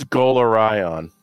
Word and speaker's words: Golarion: I need a website where you just put pronunciations of every single character in Galarian, including a pronunciation of Galarian. – You just wing it. You just go with it. You know? – Please, that's Golarion.